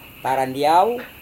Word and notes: tarandiau: Serewen

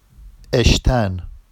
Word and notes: eʃtan: Vafsi

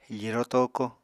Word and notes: firo toko: apatani

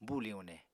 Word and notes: buli uune: Apatani

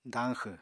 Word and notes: dañxw: apatani

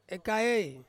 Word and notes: kaei: Ansus

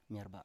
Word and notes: myerba7: Biak

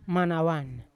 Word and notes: manawan: Yawa

